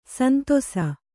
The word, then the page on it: ಸಂತಸ: Kannada